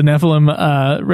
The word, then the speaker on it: Nephilim: I'm going to call it Niflheim because I've been playing Final, or I've been watching fucking Kingsglaive, which there's a town called Niflheim, and I'm realizing that's why I'm saying it wrong.